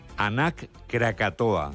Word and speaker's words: Anak Krakatoa: Nos vamos a Indonesia, donde la erupción del volcán, vamos a ver si lo decimos bien, Anak Krakatoa.